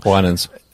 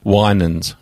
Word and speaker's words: Winans: Williams is talking about in that song. – Winans. – How do you say it? – Winans.